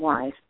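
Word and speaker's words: Wyss: And Martin, I think he pronounces his name Weiss, but it's W-Y-S-S.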